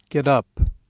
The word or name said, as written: get up